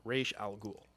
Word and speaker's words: Ra's al Ghul: So in whatever language it actually is, Arabic, Sumerian, whatever it is, it's actually pronounced Ra's al Ghul. So properly pronounced.